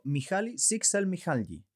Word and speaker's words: Mihaly Csikszentmihalyi: nuestro amigo Mihaly Csikszentmihalyi.